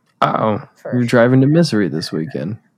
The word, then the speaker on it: Missouri: I'm pronouncing that right. Yeah, correctly, yeah, right? – For sure. – Missouri? That's the state name.